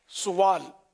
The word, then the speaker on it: سوال: ایک تو لفظ سوال ہے یا سوال, تو صحیح تلفظ اس کا یہ کہ سین پر پیش سوال, اور